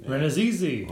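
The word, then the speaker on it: Rannazzisi: I can't pronounce it. Ranazizi? – Ranazizi. – Oh, okay. Not as... It's like Mississippi. Once somebody said it, you're like, oh, that wasn't that bad.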